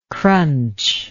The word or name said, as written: crunch